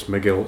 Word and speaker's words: McGuill: I'm pretty sure it's McGill. It's spelled M-C-G-U-I-L-L.